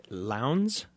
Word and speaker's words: Lowndes: It was written in 1914 by Marie-Belloc Lowndes, which rhymes with Towns.